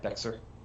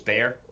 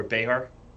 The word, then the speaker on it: Bexar: Well, it reads Bexar, B-E-X-A-R. But okay. For some reason, the X is pronounced as an A, so it's Bear. Bear okay. Or Behar.